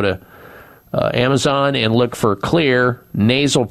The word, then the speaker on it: Xlear: And so you need to keep your nose clean with a therapeutic nasal spray called Clear, X-L-E-A-R. That's how you spell it, X-L-E-A-R, nasal spray.